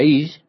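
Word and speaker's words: Ish (אִישׁ): Esta será llamada varona, Isis, en el idioma hebreo, porque del varón, se pronuncia Ish, fue tomada.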